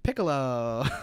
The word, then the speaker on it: Piccolo: And Tien has to save Launch because the waterfall is collapsing and water is shooting out everywhere now because of what Piccolo. That's not how you say his name. Piccolo.